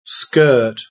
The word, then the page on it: skirt: British English